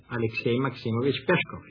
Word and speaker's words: Alexei Maximovich Pieshkov: Alexei Maximovich Pieshkov. – Maxim Gorky. – Yes, Maxim Gorky is right. How do you say his real name? – Alexei Maximovich Pieshkov. You did it right.